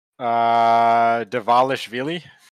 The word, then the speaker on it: Dvalishvili: Dvalishvili.